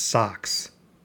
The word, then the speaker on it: SOSCS: My wife promptly named it SOSCS, Own Sphere Computer System, pronounced SOCKS.